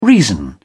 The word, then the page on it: reason: British English